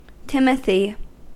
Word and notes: timothy: American English

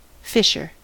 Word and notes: fisher: American English

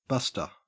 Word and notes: buster: Australian English

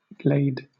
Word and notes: glade: British English

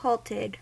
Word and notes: halted: American English

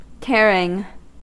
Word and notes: tearing: American English

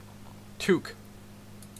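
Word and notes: toque: Canadian English

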